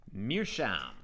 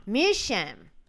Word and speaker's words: Meerschaum: C-H-A-U-M. How would you pronounce it? – Meerschaum. – Meerschaum.